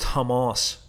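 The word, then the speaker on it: Tomás: And interestingly, I was chatting to her tour guide, Thomas, pronounced Tomas, that I keep doing it anyway and ruin it with the Irish pronunciation.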